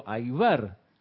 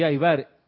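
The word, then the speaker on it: Aybar: Digo, Ramiro Aibar. ¿Y su apellido? Me preguntan. Digo, Aibar. Sí, sí, Aibar. ¿Y cómo se escribe? A Y B alta A R.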